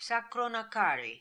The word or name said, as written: Sakronakari